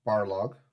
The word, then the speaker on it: Barlog: Because Sony Santa Monica Studio creative director Corey Balrog has... – Barlog. He's not the Balrog.